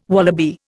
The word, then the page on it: wallaby: Indian